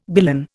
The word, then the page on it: billon: Indian